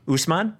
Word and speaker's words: Usman: Okay, so Masvidal and U-S-M-A-N. – Usman. – Usman?